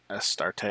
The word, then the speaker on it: Astarte: Astarte, uh, Ceres. a new start. Uh, no, it's like Syria's a start, Astarte.